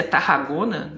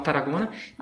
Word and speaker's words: Tarragona: Tarragona, que se escreve com dois R's, né? A gente lê em português seria Tarragona, né? Se vocês forem procurar, mas eles falam Tarragona.